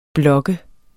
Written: blog

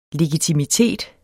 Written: legitimitet

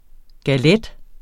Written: galette

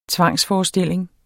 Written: TVANGSFORESTILLINGERNE

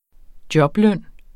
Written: jobløn

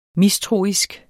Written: mistroisk

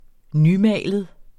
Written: nymalet

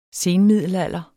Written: senmiddelalder

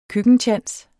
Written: køkkentjans

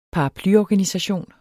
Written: paraplyorganisation

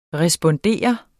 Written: respondere

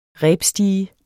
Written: rebstige

